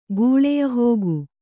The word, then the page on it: ಗೂಳೆಯಹೋಗು: gūḷeyahōgu